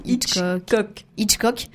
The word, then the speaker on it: Hitchcock: Hitchcock.